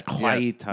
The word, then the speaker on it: Guaita: Is that, am I saying that right? – I think so. – Guaita.